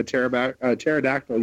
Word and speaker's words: pterodactyl: It's, why can't you hear a pterodactyl using the bathroom? – Because the P is silent.